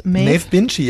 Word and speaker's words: Maeve Binchy: Das ist von Maeve, spricht man das so aus? – Maeve Binchy, ja, die berühmte Unterhaltungsroman-Bestseller-Autorin.